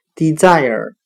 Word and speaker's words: desire: 今天所学的单词 desire，渴望，我们就可以通过它的发音联想到汉语的滴在耳，请再多下几滴雨吧。 desire，渴望。